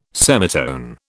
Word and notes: semitone: British English

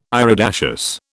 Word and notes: iridaceous: British English

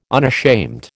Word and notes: unashamed: American English

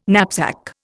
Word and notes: knapsack: Indian

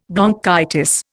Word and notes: bronchitis: Indian

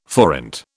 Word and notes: forint: British English

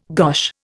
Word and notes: gosh: Indian

